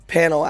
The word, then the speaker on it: Pannell: Pannell, actually.